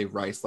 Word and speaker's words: Rice: I say Rice, like the food.